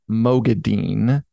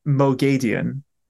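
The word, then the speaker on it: Moghedien: Well, what I will say is I watched the season 2 finale yesterday, and what Lanfear says is Mogadeon. – Mogadeon. – So she says it kind of Mogadeon. Yeah, kind of like that. So it is kind of in the middle of all these pronunciations. Yeah, so kind of like Mo Gay, like G-E-G-H-E-H is the way I've seen it like broken down.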